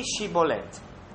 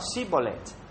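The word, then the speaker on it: Shibboleth: Se quello rispondeva no, i Galaditi gli dicevano: ebbene, di Shibolet. E quello diceva: Sibolet, senza fare attenzione a pronunciare bene.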